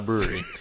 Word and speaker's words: Bruery: That's the name of the brewery is The Brewery. And it's not spelled, you know, brewery. It's spelled B-R-U-E-R-Y. – Yeah. – So. – Brewery. – Brewery.